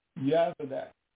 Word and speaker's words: Jozadak: And we really should pronounce those J's with a Y, just like with Jerusalem should be Jerusalem. And Yeshua and the son of Jozadak,